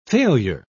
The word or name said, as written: failure